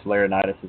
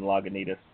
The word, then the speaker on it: Laurinaitis: I have some radio friends out in the Bay Area, and when, let's see, which Laurinaitis is he? James Laurinaitis got cut loose by the Rams. They wouldn't say his name right. They kept calling him Lagunitas.